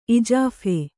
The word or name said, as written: ಇಜಾಫೆ